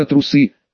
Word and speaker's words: трусы: какие тренера трусы, с ударением на «у».